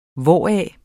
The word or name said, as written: hvoraf